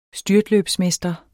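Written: styrtløbsmester

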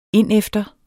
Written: indefter

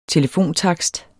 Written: telefontakst

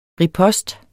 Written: ripost

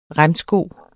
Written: remsko

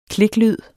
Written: kliklyd

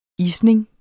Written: isning

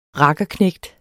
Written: rakkerknægt

